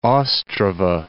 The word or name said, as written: Ostrava